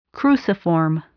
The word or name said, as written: cruciform